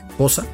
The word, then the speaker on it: Xhosa: La melodía Nkosi Sikelele Africa es una melodía de idioma Xhosa, o para pronunciarlo bien, Xhosa. Sí, lo escucho bien, Xhosa.